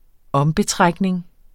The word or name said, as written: om